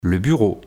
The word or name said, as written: le bureau